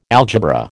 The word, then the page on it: algebra: American English